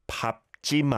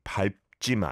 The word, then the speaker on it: 밟지: The pronunciation is the point. 스승의 그림자도 밟지 마라. That is how the dictionary says and rules dictating Korean say you should say it, but many people say 밟지 마라, okay?